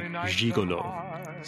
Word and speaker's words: gigolo: And he's the only American singer who's recorded it that I've heard that pronounces it gigolo. Still puts that very French sound on it.